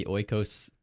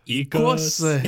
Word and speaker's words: Oikos: Are you as annoyed by the Oikos ads as I am? – No. – No? – I like Oikos, yeah. which is actually pronounced Ikos. – Exactly.